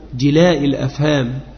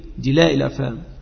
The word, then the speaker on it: جلاء الأفهام: جلاء الأفهام. بعض إخواننا ينطق هكذا جلاء الأفهام، الجلاء الرحيم، جلاء الأفهام أي الجنون، فيقولون جلاء الأفهام، بل جلاء، الجلاء من الصقر كما تجل الشيء، فجلاء الأفهام صقل الأفهام بمعرفة فضل الصلاة والسلام على النبي الهمام صلى الله عليه وعلى آله وسلم.